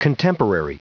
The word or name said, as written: contemporary